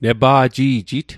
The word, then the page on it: nepa'ji'jit: Mi'gmaq